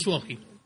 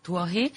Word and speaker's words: Tuohy: Creo que lo digo bien, porque es un apellido un poco... – Sí, es Tuohy. – Tuohy.